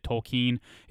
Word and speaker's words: Tolkien: So, for those of you who are offended by it, let me tell you that if he was alive today, he would prefer people to pronounce it the way they did, Tolkien. It is the proper German pronunciation, and he was a stickler for words because, you know, he's a professor of languages.